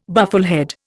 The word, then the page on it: bufflehead: Indian